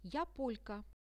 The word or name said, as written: ja polka